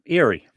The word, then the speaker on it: Eyre: It's called the Iron, E-Y-R-E. How do you say that? – E-Y-R-E. Erie. – Erie. So it's kind of like the guy from Winnie the Pooh, huh? – Yeah, that's right. – The donkey. – Like Eeyore. Yeah.